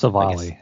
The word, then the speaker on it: Civale: Are we calling it Civale? Is that how you say it? Civale? – I think it's Civale, yes.